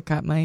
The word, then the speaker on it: Katmai: Katmai. – Okay. – Katmai National Park website. – I would say Katmai, yeah.